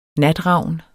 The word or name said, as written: natravn